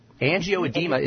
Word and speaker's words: angioedema: I think it's angioedema. – Pronounced G-I-O-E-D-M-A.